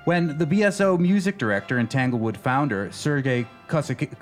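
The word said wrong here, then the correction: Koussevitzky: how do I say that? Kuzovitsky. Kuzovitsky.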